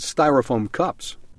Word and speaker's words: styrofoam: We need to stop using disposable plastic plates and styrofoam cups. – Styrofoam cups, 일회용 접시를 말하는데요. 많은 분들이 styrofoam을 스티로폼이라고 말하는데요. 미국 사람들은 그렇게 말하면 못 알아듣습니다. 스티로폼 컵은 정확한 발음이 styrofoam cup 이렇게 말씀을 하셔야 됩니다. S-T-Y-R-O-F-O-A-M, 발포성 합성 수지 스티로폼입니다.